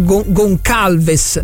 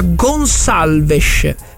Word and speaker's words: Gonçalves: Gonçalves invece è, io vedo che molti dicono Gonçalves, no, non è Gonçalves perché c'è la ciccedigliata, si dice, si pronuncia Gonçalves, poi la S uno la può arrotondare oppure no, però è sicuramente la C centrale è Gonçalves, non è Gonçalves, quindi lo dico anche a voi perché è chiaramente portoghese e quindi si pronuncia in questa maniera.